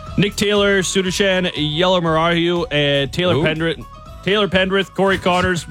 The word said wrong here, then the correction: Yellamaraju: Sudarshan Yellamaraju. – No. – Close. Which part am I missing? – Both. – Sudarshan. – Okay. – Yellamaraju. – Yellamaraju. – Maraju? – Yep.